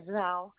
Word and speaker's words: Zhao: The last name is Zhao.